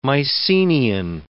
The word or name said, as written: Mycenian